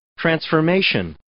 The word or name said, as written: transformation